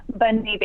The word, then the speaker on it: Bonne Nuit: Did I say that right? – You did, yes. – Okay, can you say it so it sounds super fancy in French and official? – Bonne Nuit Baby.